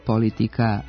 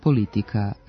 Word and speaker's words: politika: Navedeni pridevi nisu usamljeni primjeri nenormiranog prenošenja akcenta na prvi slog, već istu pojavu prepoznajemo i u izgovoru imenica Amerika, politika, republika.